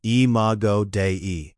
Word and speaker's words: Imago Dei: It's a hardware feature of the Imago Dei, pronounced Imago Dei.